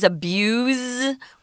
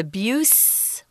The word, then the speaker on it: abuse: So the verb is abuse with a Z, and the noun is abuse with an S.